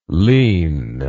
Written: ligne

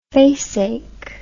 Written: faceache

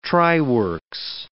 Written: tryworks

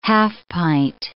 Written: half-pint

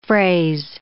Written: fraise

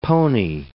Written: pony

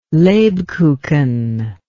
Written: lebkuchen

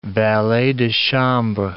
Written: valet de chambre

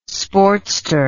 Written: sportster

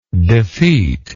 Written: defeater

